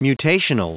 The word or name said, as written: mutational